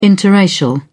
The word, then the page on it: biracial: British English